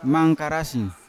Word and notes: mangkarasi: Yawa

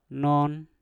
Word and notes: non: Mpur

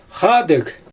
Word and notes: xaadɯɣ: Xyzyl

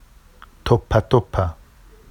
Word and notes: toppatoppa: Vafsi

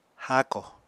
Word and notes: háako: apatani

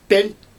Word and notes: pelJ: Xyzyl

